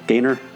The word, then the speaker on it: Gaynor: Is that how you say her name? – Gaynor. – Gaynor. Yeah. C.